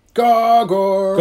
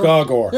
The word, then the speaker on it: Gogor: In the back, in the actual editor's letters in the back, it says that you're meant to pronounce it Gaw-Gor. – Gogor. Yeah, see, it's Gogor.